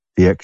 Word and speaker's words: Dyack: Rhymes with Shaq. Dyack.